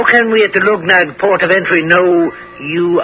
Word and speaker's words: Luggnagg: He arrived up the river Clumegnig, right outside the town of Lugnag. Oh, I'll spell that for you. L-U-G-G-N-A-G-G.